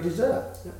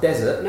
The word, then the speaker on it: dessert: Yeah, and then you have your dessert. – Dessert. Careful with the pronunciation of this word. – Yeah, because it's not desert.